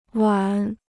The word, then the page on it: 碗: wǎn